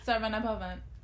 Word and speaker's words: Saravana Bhavan: And then, you know, I don't know if I'm saying it right, but Saravana Bahan. But is that how you say it? I don't know. – Yeah. Saravana Bhavan.